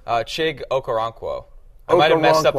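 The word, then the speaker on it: Chig Okonkwo: I could see, and I'm gonna butcher his name. Okonkwo? – Oh, Chig? – Yeah. – Chig Okoronkwo? – Yeah, thank you.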